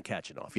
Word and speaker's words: Khachanov: Karen Khachanov.